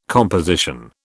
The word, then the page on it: composition: British English